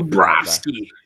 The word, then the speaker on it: Bobrovsky: You told me I have to pronounce it that way. Don't think I Bobrovsky.